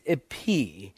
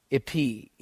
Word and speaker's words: epi: And then the third Greek preposition is epi, or some people pronounce it epi, epi, E-P-I, para, en, and epi.